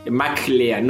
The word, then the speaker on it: MacLean: M-A-C-L-E-A-N. McLean, no M-C. Mac como la computadora, no como McDonald's. – Exactamente. Y lean. – Lean como el consejo.